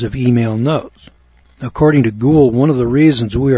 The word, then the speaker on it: Gould: Now, one of the advocates of this flaky idea is a guy named David Gould, G-O-U-L-D, spelled or pronounced Gould, who has a web page named The Amazing Vision of David Gould, where he promotes this trash.